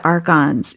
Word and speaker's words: archons: So I want to also have you dip more deeply into the archons. Is that right? Am I pronouncing it correctly? – Mm-hmm. Yeah. Yeah, the archons are Wetiko, or many names, alien parasites, extraterrestrials.